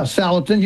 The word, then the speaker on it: Salatin: Yeah, Salatin.